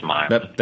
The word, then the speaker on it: Maila: I kept finding her name spelled M-Y-L-A. – Well, there you go. – Essentially, people spelling it like they were hearing it pronounced, so I'm pretty sure it's Myla.